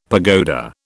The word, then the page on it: pagoda: British English